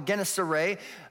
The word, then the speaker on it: Gennesaret: He was standing by the lake of Gennesaret. Now, some will call it Gennesaret or Gennesaret, but if you Google it, you'll find that the accurate pronunciation is Gennesaret.